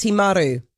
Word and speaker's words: Timaru: It's pronounced Timaru.